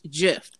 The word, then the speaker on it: GIF: The creator of the GIF, okay, said that it's pronounced GIF. Soft G.